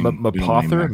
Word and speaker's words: Mapother: It's spelled M-A-P, then it's like Map Other. Yeah, put the word Map and Other together. That's his name.